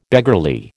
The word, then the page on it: beggarly: American English